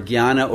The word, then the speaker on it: jnana: And the word jnana is often translated as knowledge. But remember, jnana or jnana, as it's pronounced in the Gujarati, what derives from that is the word gnosis in the Greek.